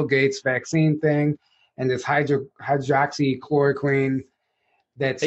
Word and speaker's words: hydroxychloroquine: Hey, you said it right. – Yeah. – Yeah. – It's so you said it better than most of the people on stage up there. – I know. Most people are like hydrochloroxine.